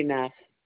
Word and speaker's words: Neff: Sandy Neff.